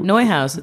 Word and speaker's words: Neuhaus: Neuhaus. It's like a German pronunciation.